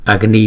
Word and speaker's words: Agni: Chúng ta không có đọc là Agni mà là Aga, G, Agni Swaha, Agni Swaha.